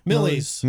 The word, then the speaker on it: Millyz: Um and then Sunday night we got Mil, Mil How does he pronounce it again? Millsy? Millyz. Millyz. That's what I thought.